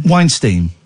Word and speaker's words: Weinstein: Weinstein.